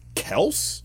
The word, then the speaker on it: Kelce: and Travis Kelce, who also apparently his name is pronounced Kels,